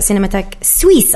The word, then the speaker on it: Cinémathèque Suisse: I should thank you, Frederic Mayer, the ex-director of Locarno Film Festival, and who also works at Cinémathèque Suisse, I said it correctly that time, yeah.